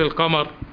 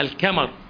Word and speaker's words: القمر: لماذا يقول السوءة السوءاء في ذكر القمر؟ الكا كا الكمر، تنطق القاف كاف، القاف ثقيلة على الأعجمي الأجنبي، فإذا قال الكمر بالكاف هكذا فالمقصود بها رأس الذكر، فهذه كلمة شديدة عنيفة يعني.